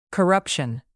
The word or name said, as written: corruption